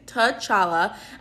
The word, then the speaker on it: T'Challa: Like, how do you even pronounce his name? I think it's T'Challa, and it's from the movie Black Panther, which I should watch, but I have not seen.